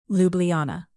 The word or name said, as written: ljubljana